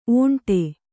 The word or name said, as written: ಒಂಟಿ